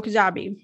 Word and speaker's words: Kajabi: Well, let's talk about, and I hope I'm saying this right, is it Kajabi? – Well, yes, of course. So Kajabi. – Mm-hmm. Yes. All right. So what is it? Talk us through this. – All right. Well, okay. So Kajabi,